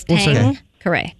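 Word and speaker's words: Tanqueray: It's Tankeray. It says it. Tankeray. It's three syllables. Tankeray. – Tankeray. – T-A-N-G-K-R-A-Y.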